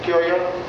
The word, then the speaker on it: Curia: And the Roman Pope was like this because the cardinals formed the Curia of Rome. You see, how do you pronounce it in English? I don't know. Curia?